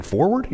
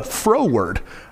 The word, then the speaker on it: froward: You're thinking forward? You mean forward mouth? No, froward. Froward.